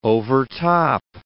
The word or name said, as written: overtop